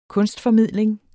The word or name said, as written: kunstformidling